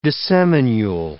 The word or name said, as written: disseminule